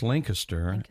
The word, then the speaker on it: Lancaster: Now, it looks, many people would pronounce it Lancaster. – Everyone does. Yeah. Yep, but you pronounced it correctly. – Yeah, because if you're from the area or if you visited the area, it's Lancaster, and you're right.